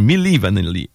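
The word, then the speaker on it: Milli Vanilli: Comme M-I-N-I. – Oui, oui, c'est vraiment M-I. Non, Millie. Excuse-moi, je l'ai mal prononcé. Millie Vanilli. M-I-L-L-I. Puis écrit V-A-N-I.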